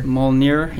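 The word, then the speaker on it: Mjölnir: Mjolnir, I don't know. Am I pronouncing that right? Mjolnir? – Mjolnir, yeah.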